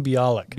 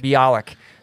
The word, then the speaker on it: Bialik: Mayim Bialik. – Bialik.